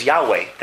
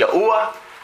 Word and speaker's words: Yahweh: And the name is Yahweh. That's the way you pronounce it. That's the way it is. It's not Yahuwah.